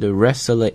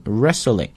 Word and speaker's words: wrestling: 那么在这儿呢提到了这个组织啊，叫 World Wrestling Entertainment。注意一下这个单词的发音 ,wrestling。我再读一遍啊 ,wrestling。大家发现哪个字母实际是不发音的呢？哎，其实就是这个 W,W 不发音，直接 R 字母的那个音出来，叫 wrestling, 表示的意思是摔跤。